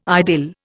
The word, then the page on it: idyll: Indian